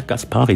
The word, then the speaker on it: Kaspari: Letztes Mal sagtest du Kaspari. Das heißt Kaspari. – Kaspari. – Ja, Kaspari. Die Betonung auf der zweiten Seite.